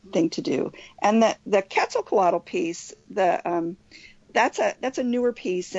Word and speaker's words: Quetzalcoatl: It's Quetzalcoatl.